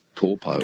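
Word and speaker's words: Taupō: Now it's called Taupo. – Taupo, yeah, that's right. It's just the vowel pronunciations are a bit different. They don't really have that ow sound, like cow, meow.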